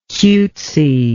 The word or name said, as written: cutesiness